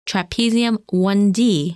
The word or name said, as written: Trapezium 1D